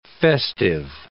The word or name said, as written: festive